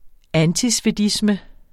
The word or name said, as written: antisvedisme